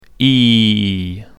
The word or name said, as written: ee